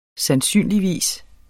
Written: sandsynligvis

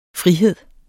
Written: frihed

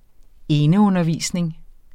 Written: eneundervisning

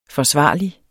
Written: forsvarlig

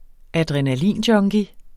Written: adrenalinjunkie

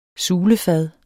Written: sulefad

